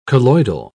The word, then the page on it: colloidal: British English